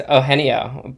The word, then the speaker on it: Eugenio: I think I pronounced that correctly. – You didn't. But that's okay. – How do you pronounce that? – It's Eugenio, but it was you did a really good job.